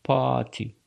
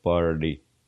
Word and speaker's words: party: Если бы я был английским лордом, я бы говорил party, party. Но так как я не английский лорд, я говорю, как обычно говорят американцы. Они произносят букву R. Но буква Т после R, party, звучит более как-то звонко, я не знаю, как еще это вам объяснить, более грубо, и получается party, как будто бы я говорю party, party, нет, party, Christmas party.